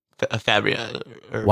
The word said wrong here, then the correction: Faberge: It's Fabric. I can't say it right, but that's a... – Faberge. – Yeah. – Russia. – Yes. – Yeah, that's probably right. – Faberge. Wow. – Faberge. That's it. Yeah.